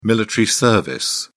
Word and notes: military service: British English

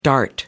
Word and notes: dart: American English

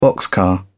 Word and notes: boxcar: British English

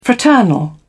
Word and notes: fraternal: British English

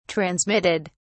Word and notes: transmitted: British English

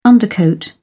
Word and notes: undercoat: British English

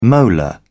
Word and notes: molar: British English

